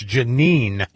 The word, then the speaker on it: Jeanine: Judge Jean Pirro? – Yes, Jeanine Pirro. – I can't ever say her name properly. It's Jeanine. – Yes. – Not Jean.